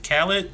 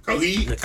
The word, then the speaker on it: Khalid: Now the one of our ideas get put up on recently that's really dope is was it Khaled Khalid, I may be saying his name wrong. Khalid.